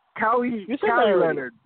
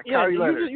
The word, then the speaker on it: Kawhi Leonard: Keanu Leonard. – Kawhi Leonard. There you go. Yeah, Kawhi Leonard. – You just said that already. – Yeah, and I was trying to pronounce it correctly. – Yeah. – I just said Leonard, but I was trying to pronounce the full name. But it's Kawhi Leonard.